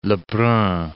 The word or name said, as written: Lebrun